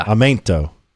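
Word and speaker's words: aumento: It's amento. But the E makes an A sound, so, or an A sound. – Yeah. – Amento.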